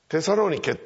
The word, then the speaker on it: テサロニキ: 例えばピリピの教会、今ピリピに行きましても、ここにピリピという町があったんだという遺跡ぐらいしか残っておりませんけれども、テサロニケっていうのは今でもギリシャ北方で大きな町です。栄えてる町です。多分発音はテサロニキっていうふうに言うんだろうと思うんですけど、まあ同じことです。